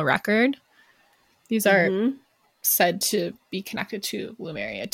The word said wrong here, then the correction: Lemuria: too. Lemuria. I always say Lumeria, but it's Lemuria.